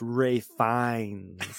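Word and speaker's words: Ralph Fiennes: It's pronounced Rafe Fiennes.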